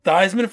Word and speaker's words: Theismann: Theismann for Heisman. Fun fact, his last name's Theismann. He changed the way they say his name just to rhyme for Heisman.